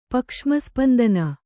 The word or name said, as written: ಪಕ್ಷ್ಮಸ್ಪಂದನ